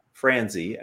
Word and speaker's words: Franzi: I'm Rick, which is spelled R-I-C, Franzi, F-R-A-N-Z-I.